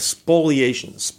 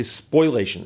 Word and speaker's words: spoliation: And so I've talked before and briefly used the concept in discussions of spoliation. And people often say spoilation, and they're mispronouncing the word. It's like foliage, not foliage. Spoliation is S-P-O-L-I-A-T-I-O-N, spoliation.